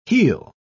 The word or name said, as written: he'll